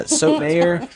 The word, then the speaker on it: Sotomayor: Sonia Sotomayor? Yeah, Sotomayor. – In Miami, everybody would be saying it correctly.